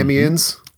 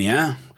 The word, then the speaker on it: Amiens: He was a uh, teacher in Amiens. How did you say it? Mm-hmm. – Amiens. – Mm-hmm. Amiens.